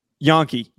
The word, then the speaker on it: Janke: Jaxon Janke is very, very good and is Yankee, Yankee, Yankee. I said it right. Yankee. It's Jackson Yankee.